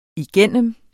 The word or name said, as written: igennem